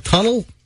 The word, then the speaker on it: tunnel: I said it wrong, didn't I? – Yeah, I think you did. A carpal tunnel. – Tunnel. That's it. Not tunage.